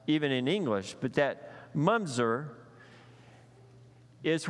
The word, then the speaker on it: mamzer: It's spelled M-A-M-Z-E-R. I thought it was Mamzer. It's actually Mumzer, even though it's M-A-M-Z-E-R. So the Hebrew for that is Mumzer.